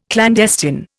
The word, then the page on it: clandestine: Indian